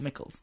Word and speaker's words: Michels: But it's spelled like M-I-C-H-E-L-S. – Michaels.